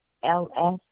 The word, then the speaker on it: Huels: The last name is HUELS. – Can I get your zip code, please? – 24015. – You said the last name is spelled HUELL? – LS. HUELS.